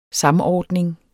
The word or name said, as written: samordning